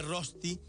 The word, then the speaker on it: rösti: Entonces, por ejemplo, hacen que la O con diéresis sea una OE. Claro, entonces lo que se escribe rösti es una rösti.